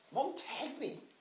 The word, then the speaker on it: Montagny: Montagny? – You don't pronounce the G, it'd be silent. – I don't want to see him. He's an F-R-A-N-C-K.